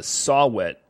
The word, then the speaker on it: saw-whet: We hear that little Rockefeller is a saw-whet owl. Am I saying that right? – Yes.